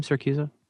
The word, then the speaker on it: Siracusa: Is that how you say his name, Siracusa? – Yeah, there's no Z in Siracusa.